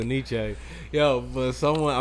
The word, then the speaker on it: Nietzsche: I'm pretty sure that was a Nietzsche shirt I had on. – Fuck it. Is that how you pronounce it? I always thought it was Anisi. – No.